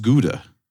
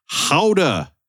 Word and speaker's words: Gouda: and she told me that the cheese term is not Gouda. It's not pronounced Gouda. – Okay. – She went to the Danish city that it's named after, and it is pronounced Howda. Howda with an H.